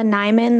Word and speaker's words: Nyman: My name is Bella Nyman. That's spelled N-Y-M-A-N.